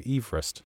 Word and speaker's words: Everest: I've heard it said that the gentleman after whom Everest is named actually pronounced his surname Everest.